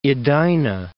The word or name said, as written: Edina